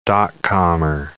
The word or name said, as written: dot-commer